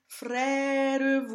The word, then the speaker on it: frère: And in the very beginning of this recitative, we have the phrase frère voyer. Even though the schwa ends up on a beat, it's very important to unstress this syllable. In normal speech, this syllable wouldn't even exist.